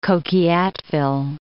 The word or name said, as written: Coquilhatville